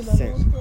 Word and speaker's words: scent: Is the S or the C silent in scent? – The C is silent, right? – My throat felt weird after I said that.